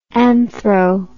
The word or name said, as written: anthro